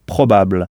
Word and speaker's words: probable: Là encore, on insiste en anglais sur la syllabe qui précède cette terminaison, donc « probable ». On frappe en quelque sorte sur cette syllabe « probable ».